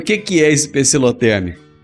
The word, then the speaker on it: pecilotérmico: E eu não conseguia lembrar de jeito nenhum, e agora eu me lembrei dessa palavra, que é o tal do pecilotérmico.